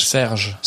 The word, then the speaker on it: Serge: How do you say Serge? – Serge. – Serge. So, I'll say it in an English accent.